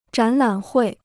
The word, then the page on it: 展览会: zhǎn lǎn huì